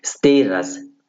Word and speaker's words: Steiras: Este y esa palabra este que se tradujo este como, como estéril es la palabra eh, Steiras que se escribe e S-T-E-I-R-A-S, stiras.